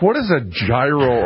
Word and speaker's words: gyro: Would you have thought that the G would be silent? Gyro.